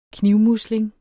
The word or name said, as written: knivmusling